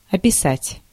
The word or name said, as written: описать